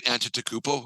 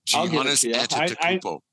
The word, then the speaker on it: Antetokounmpo: Did I say that right, Antetokounmpo? I think I got that right, didn't I? Real close. Giannis Antetokounmpo.